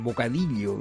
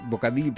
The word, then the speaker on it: bocadillo: Pronuncia muy bien la L. – Sí, claro. – Bocadillo. – Bocadillo. Yo soy incapaz, porque para mí la L y la Y suenan igual.